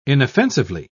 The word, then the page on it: inoffensively: British English